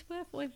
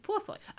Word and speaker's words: Purefoy: Yeah, no, in Altered Carbon, you see every little bit of James Purefoy.